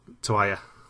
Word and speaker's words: toalla: I thought there was the J. I thought he pronounced the double L as a J there. – No, he pronounced toalla, because I wrote it down deliberately as the only pronunciation.